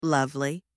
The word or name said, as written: lovely